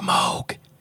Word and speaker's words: Moog: It's pronounced Moog. It's a synthesizer. – It is a synthesizer. – Oh, trust me. – Oh, yeah. Moog.